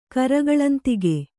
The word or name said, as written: ಕರಗಳಂತಿಗೆ